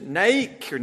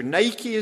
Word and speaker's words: Nike: Was it Nike, or Nike as the Americans say?